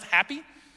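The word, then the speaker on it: Hapi: Uh, for example, there was a, a, god, and this is literally, it's H A P I, but it is pronounced happy.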